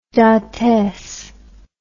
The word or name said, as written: dantes